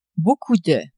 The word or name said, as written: beaucoup de